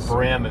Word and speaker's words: bream: He actually used the word bream. – Or brim, as I would pronounce it.